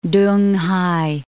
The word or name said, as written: Tunghai